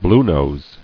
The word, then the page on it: Bluenose: blue·nose